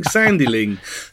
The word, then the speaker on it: Sandling: isn't weird, but when you stop and think about it, nobody else has that surname, so everyone doesn't quite know how to pronounce it. Everyone's like Sandling.